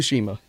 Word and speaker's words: Tsushima: Ghost of Tsushima.